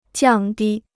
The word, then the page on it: 降低: jiàng dī